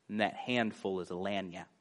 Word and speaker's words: lagniappe: It's a French Creole word, and the word is lagniappe.